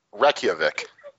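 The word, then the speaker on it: Reykjavík: It's and no joke, it's pronounced Reykjavik.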